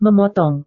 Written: memotong

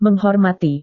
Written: menghormati

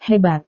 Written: hebat

